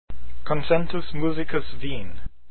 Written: concentus

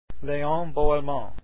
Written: boellmann

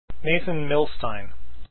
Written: milstein

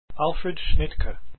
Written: schnittke

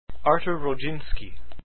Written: rodzinski

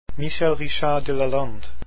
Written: delalande

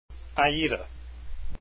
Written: aida

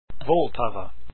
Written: Vltava

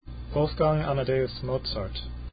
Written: mozart

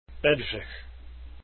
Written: bedrich

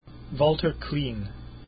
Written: klien

